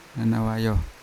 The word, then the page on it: anawayo: Yawa